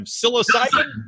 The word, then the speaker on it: psilocybin: psilocybin. I'll say this wrong. Help me here, people. You're both far more involved in this than I am. Psilocybin? – Decriminalization of psilocybin. – Bingo, baby.